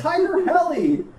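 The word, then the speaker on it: Tiger Heli: Is that how you say it? I've been saying Tiger Healy since I was 5 years old. – Helicopter. – Oh, it's Tiger Helly. Man, I really thought it was Tiger Healy all these years.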